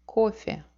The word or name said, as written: kofe